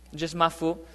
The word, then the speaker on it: Mafu: In Portuguese, there's no TH sound, so that's where Mafu came from. It's not from outer space, it's just Mafu.